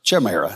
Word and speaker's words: chimera: You see, now there again, and can anybody pronounce that word there? Chimera? Chimera. That would be it.